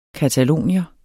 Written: catalonier

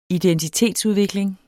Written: identitetsudvikling

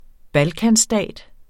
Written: balkanstat

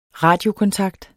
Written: radiokontakt

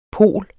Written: pol

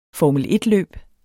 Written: formel

